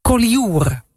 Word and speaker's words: Collioure: Maar ze zit dus in Collioure. – Is het goed uitgesproken, Sietse? – Perfect.